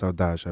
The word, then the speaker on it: saudade: It's in Portuguese and it's untranslatable apparently, and it's saudade. – And that's saudade. – It's got two, it's spelt saudade, but it's pronounced saudade, I believe.